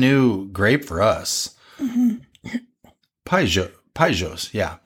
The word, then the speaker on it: Paysage: So this particular is a 2018 Chateau Paysage. I had to remember how to pronounce that. Did I say it right? – Paysage. – Paysage? Paysage. That's right. – Paysage, actually.